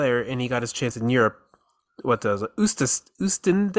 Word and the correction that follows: Oostende: then? I can't. Remember, I don't know how to Oostende, pronounce it. – Yeah, Oostende, yeah.